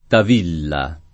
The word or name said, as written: Tavilla